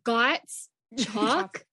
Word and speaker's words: Gottschalk: Well, for the longest, I thought it was Gottschalk. It's Gottschalk. – Like you got chalk for your chalkboard. – So the S is silent.